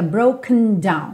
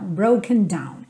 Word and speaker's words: broken: Percebe que esse broken, esse E não aparece muito, não é broken down, fica broken down, broken down. Ok? A sílaba tônica é a primeira, então naturalmente a tendência da palavra realmente é ir murchando depois da sílaba tônica, e ainda mais esse N no final da palavra faz com que ela feche mesmo, ok?